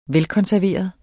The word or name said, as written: velkonserveret